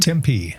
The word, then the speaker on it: Tempe: Tempe.